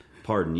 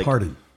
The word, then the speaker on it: Pardon: Molly Pardon. – Pardon. Yeah. Like Pardon. – Okay. Yeah, it's not spelled like Dolly Parton. It's Pardon, okay.